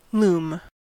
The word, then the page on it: loom: American English